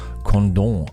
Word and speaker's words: Condom: Aber weil das in Frankreich liegt, wird es natürlich ganz edel Condom ausgesprochen.